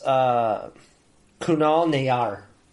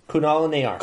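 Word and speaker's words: Kunal Nayyar: Nayel his name is Kunal Nayar. Kunal Nayar. – Kunal Nayar.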